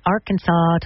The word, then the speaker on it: Arkansas: And believe it or not, Arkansas took the top spot. A lot of people are still wondering why it's not pronounced like Kansas.